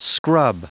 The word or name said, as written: scrub